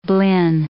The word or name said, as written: blin